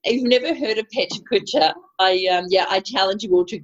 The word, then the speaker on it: Pecha Kucha: And just to, that is pronounced Pecha Kucha. – Pecha Kucha. – Pecha Kucha. I thought you said pet culture.